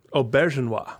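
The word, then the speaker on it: Auberjonois: Aubergenois.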